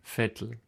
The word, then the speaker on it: Vettel: Vettel. – Aber es ist aber mit V geschrieben, nicht? – Es ist mit V geschrieben und wird auch mit Vogel V ausgesprochen. Sebastian Vettel.